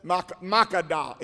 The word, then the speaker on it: Makkedah: Now the five kings had fled and hidden in the cave, which is at Makkedah, is the pronunciation.